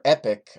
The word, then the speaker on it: Epoch: And in the DS version, you can unlock the dimensional vortex, which you can't really access until you get wings on your epoch, or epic, I think is the actual pronunciation. Epic.